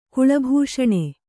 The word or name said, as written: ಕುಳಭೂಷಣ